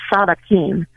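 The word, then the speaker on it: tzaddikim: Yeah, so the righteous, it's actually a Hebrew word from the Old Testament, and it's pronounced tzadikim.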